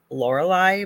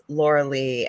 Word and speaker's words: Lorilei: But, and I understand because my name is spelled so strangely that people pronounce it probably the way it's spelt, Lorelei, but I'm not Lorelei. it's Laura Lee.